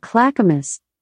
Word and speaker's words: Clackamas: Alexa, how do you say Clackamas? – I pronounce that Clackamas. But I'm always working on how I say things and I might not have it right. – Yeah. – It's perfect.